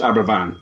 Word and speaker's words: Aberfan: That's Abervan. Spelled A-B-E-R-F-A-N. Yes. It's pronounced Abervan.